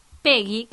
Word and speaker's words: Peggy: Bueno, mi nombre es Peggy, que se escribe con 2G y, y Belsa, que es vasco-francés, ya lo digo.